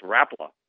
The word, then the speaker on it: Rapala: What's the official Swedish pronunciation of it? – I do believe it's Rapala.